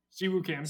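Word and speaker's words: Si Woo Kim: Si Jo Kim. Did I say that right? – Siwoo Kim.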